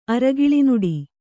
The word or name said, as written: ಅರಗಿಳಿನುಡಿ